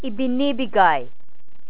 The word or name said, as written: ibinibigay